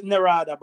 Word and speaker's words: Narada: It's like your friend, I'm not sure if I'm saying his name right. Narada? – Yeah, Neri. – Is that his name? – Neri. – Neri. – Yeah, Neri. Neri. His name is Narada, but we call him Neri.